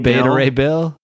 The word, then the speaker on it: Beta Ray Bill: You gotta pronounce. I'm gonna make you pronounce that right. Yeah, the word's definitely beta. Come on, beta male. – Beta Ray Bill.